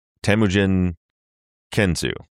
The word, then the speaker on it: Temujin Kensu: I always pronounce it wrong, Temujin Kensu, right? That's how we correct. – Pronounce his name.